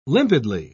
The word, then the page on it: limpidly: British English